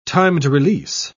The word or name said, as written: timed-release